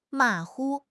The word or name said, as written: ma3 hu5